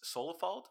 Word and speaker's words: Solefald: This next one is the band Solifold. Am I saying that right? – Yep.